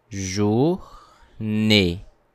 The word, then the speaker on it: journée: If you look at the word journée, you will see that there is a letter E with an accent mark on top of it. Do you remember? We learned how to pronounce it in our previous podcast. We say E. That's why the pronunciation of this word is journée.